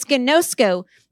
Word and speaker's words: ginosko: Gnosko. Oh, did it. That's how you pronounce it. It's ginosko.